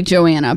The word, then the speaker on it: Joanna: Johanna, right? – Well, it's actually Joanna, but I really like pronouncing it Johanna because it just sounds like a name that is fitting for this book.